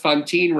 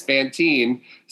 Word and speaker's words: Fantine: So that round was won by Stephanie because Brian pronounced Fantine wrong as Fantine.